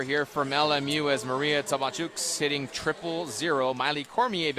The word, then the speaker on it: Tabacuks: T-A-B-A-C-U-K-S, pronounced Tabachuks.